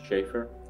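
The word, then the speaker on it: Schaeffer: Jonathan Schaefer, J-O-N-A-T-H-A-N, S-C-H-A-E-F-F-E-R.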